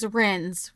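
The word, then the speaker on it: Rennes: And his name is Renz, right? – Yeah. – Okay. – Except he's go, well, just because it's spelled that way, it doesn't mean anything. I'm French. It's just pronounced Ren.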